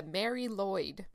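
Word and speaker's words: Mari Lwyd: Now, that's how it's pronounced. It's not spelled the Mary Lloyd. I'm going to tell you how it's spelled right now, because it's okay. Welsh, and it's like all the consonants. Mary is M-A-R-I. Pretty mm-hmm. standard. – Mary, yeah. – Lloyd is L-W-Y-D.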